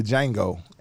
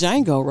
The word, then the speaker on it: Django: The Django, and that's the D is silent. – This yes. Django, right? – Yeah, the D is silent.